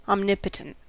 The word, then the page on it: omnipotent: American English